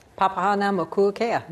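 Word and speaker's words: Papahānaumokuākea: I think can actually pronounce it. – Papahana Mokuakea. – There we go.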